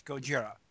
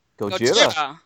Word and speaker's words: Gojira: I was pronouncing it like Godzilla. – The word is Gojira. – Gojira. That's the original.